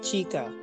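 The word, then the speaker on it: Chika: It's Chica. Like, it's almost pronounced the same way as in Spanish, like Chica for girl, but it's spelled with a K. It's spelled the second C is actually a K in Oh.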